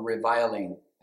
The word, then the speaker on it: reviling: Be tenderhearted, be courteous, not returning evil for evil, reveling for reveling, or reviling. I think you should pronounce it reviling.